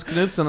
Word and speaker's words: Knudsen: I put it in S-O-N, and yeah. Which is insulting to myself because my name is Knudsen and it's spelled S-E-N, and everyone spells it wrong at S-O-N.